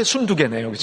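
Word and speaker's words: 순두게: 유오디아와, 발음 정확히 순두부, 순두부 맑게? 아, 이렇게 외우면 되겠네요. 그죠? 순두부 맑게 순두개네요. 그죠?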